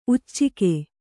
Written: ಉಚ್ಚುಕ

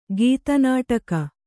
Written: ಗೀತನಾಟಕ

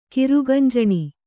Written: ಕಿರುಗಂಜಣಿ

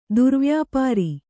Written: ದುರ್ವ್ಯಾಪಾರ

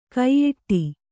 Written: ಕೈಯಿಡು

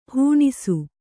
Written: ಹಣಿಸು

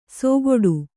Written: ಸೋಗೊಡು

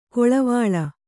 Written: ಕಳವಳ

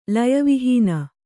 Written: ಲಯವಿಹೀನೆ

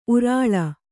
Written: ಉರುಲು

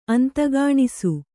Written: ಅಂತಗಾಣಿಸು

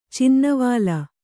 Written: ಚಿನ್ನವಾಲ

